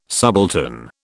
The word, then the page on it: subaltern: British English